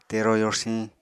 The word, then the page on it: tero yorsiñ: Apatani